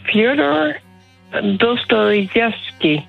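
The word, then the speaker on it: Fjodor Dostojevski: Fyodor Dostojevski.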